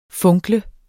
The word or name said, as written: funkle